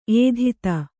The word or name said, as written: ಏಧಿತ